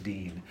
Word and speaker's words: Din: And one of the people, one of the servants is, a water carrier whose name is, he pronounced it Gunga Dean. It's spelled D-I-N, but pronounced Dean.